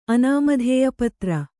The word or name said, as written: ಅನಾಮಧೇಯಪತ್ರ